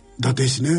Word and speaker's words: 伊達市: 伊達市ね、